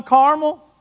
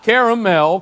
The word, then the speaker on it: Carmel: Was he not alone on Carmel? Come on. Not caramel, Carmel.